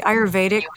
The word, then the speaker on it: Ayurvedic: Am I saying that correctly? Ayurvedic practitioner. – You are.